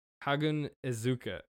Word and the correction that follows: Haganezuka: Zuka. Hagun Zuka. Yeah, here. I have his name. It's there. – Okay, where is it? Haganezuka? – Haganezuka, yeah. It's very quick pronunciation, I think.